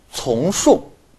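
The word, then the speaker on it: 重颂: 那么还有一种叫做从述，啊，从就是这个多音字嘛，重量的重，读从，重复的从啊，颂就是歌颂的颂。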